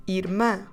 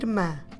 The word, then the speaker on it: irmã: Let's drop the O, we keep the accent where it is, and we finish with the sound ã, again nasal, and we say irmã, irmã. Stress the last syllable, make it nasal, mouth open this time, irmã. So if you want to say the sister, a irmã.